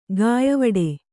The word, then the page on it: ಘಾಯವಡೆ: Kannada